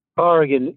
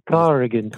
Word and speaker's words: Corrigan: Corrigan, yes. You pronounced it correctly. Corrigan. – Corrigan, of course. These Some people say Corrigan. Mm-hmm. I don't often correct people but it is Corrigan.